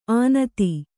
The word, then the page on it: ಅನತಿ: kannada